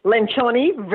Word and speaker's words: Lencioni: Okay, before we start talking about, I hope my pronunciation is right, Patrick Lencioni, how's that? – Lencioni, very close.